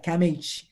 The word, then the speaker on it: CAMH: So CAMH, I didn't know that's how it's pronounced. I love that, CAMH.